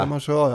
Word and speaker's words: Clemenceau: Ja, är det Clemence? – Det är helt riktigt, George Clemenceau. – Clemenceau, ja. Det är ju det här med franska uttal.